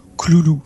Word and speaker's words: Cthulhu: Joshi, lui, il le prononce Cloulou.